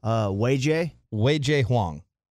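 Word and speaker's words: Wei-Chieh Huang: Wei Jays, the way they're the pronunciation guide I just got from the Rangers. – Okay, give it to me. What is it? Wei Jay Huang.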